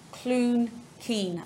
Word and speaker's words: Cloonkeen: And it's a brand coming out of Ireland called Clun Kean. Am I pronouncing that correctly? – Yes, you are indeed. You are indeed. – So Clun spelled C-L-O-O-N-K-E-E-N for people.